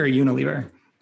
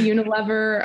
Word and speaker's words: Unilever: Is it Unilever or Unilever? – It depends on where you are. People will say Unilever. A lot of like European folks will say like Unilever, and other people will say Unilever.